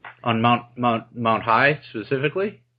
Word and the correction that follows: Hiei: It's actually pronounced Hiei. – Hiei. Oh, sorry.